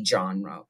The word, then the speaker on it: genre: And the reason was because as this trial was happening, the Western film or the Western movie genre was like exploding, was starting to become the genre. What are you shaking your head at, Elliot? – You continuing to pronounce genre, genre. – Genre. It's a genre.